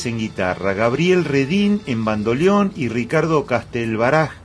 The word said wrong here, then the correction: Castelbajac: en, no lo dije mal, Castelbajac en percusión.